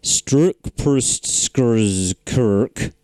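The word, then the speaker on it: Strč prst skrz krk: The Czech phrase... Strukprustskrzkrk. – Was that another rewind? – No, that's the... nice. That's the official pronunciation.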